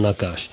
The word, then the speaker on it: Nakache: Das ist Eric Toledano und Olivier Nakache oder Nakache, auf Französisch ausgesprochen Nakache.